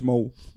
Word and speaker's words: Mow: Claro, si lo pronuncias, si lo lees como se escribe, es Mou. Pero la traducción literal sería Mu.